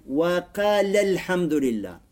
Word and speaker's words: وَقَالَ الْحَمْدُ لِلَّهِ: Читается как? Если мы соединяем, وَقَالَ الْحَمْدُ لِلَّهِ. Алиф не читается.